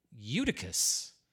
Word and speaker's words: Eutychus: And I said, so how do you pronounce this? And he goes, Eutychus.